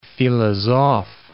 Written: philosophe

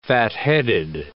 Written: fatheaded

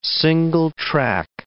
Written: single-track

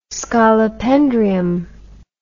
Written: scolopendrium

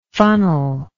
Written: funnellike

